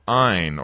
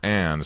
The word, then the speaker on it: Ayn: The Cult of Ayn, or Anne, some people pronounce her name, Ayn Rand.